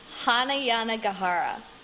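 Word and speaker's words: Hanya Yanagihara: How do you say her name? – I believe it is Hana Yanagihara.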